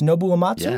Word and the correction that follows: Uematsu: Yeah. – Nobuo um, Uematsu. – Nobu Uematsu, uh, which we are all very jealous uh, this week in Japan.